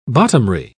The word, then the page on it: bottomry: British English